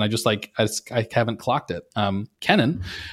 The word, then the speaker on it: Kennan: So I am listening right now to John Lewis Gaddis, the Yale historian's biography of George, is it Kennan or Kennan? I realize I actually don't know. I'm embarrassed to say I don't know how to pronounce it. It's Kennan. Okay, Kennan.